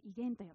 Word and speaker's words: 異言: 異なる言葉と書いて異言と読みます。